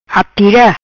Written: Atira

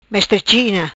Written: Mestre China

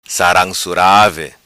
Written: Saram Surave

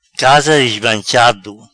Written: Casa Dismanchado

